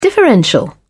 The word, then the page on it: differential: British English